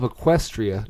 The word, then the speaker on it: Equestria: The capital of Equestria.